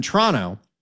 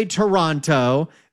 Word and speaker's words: Toronto: They don't say Toronto, they say Toronto. That's how it's done.